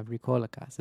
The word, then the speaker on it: Vrykolakas: Oh, that's right. We pronounce... Yeah, Vrykolakas. It's the extra syllable in there. It gets you every time.